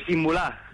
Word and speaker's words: Imbula: Oye, ¿cómo se pronuncia lo de este muchacho? – Pues Imbulá. – Imbulá, bueno, Imbulá. Muy bien.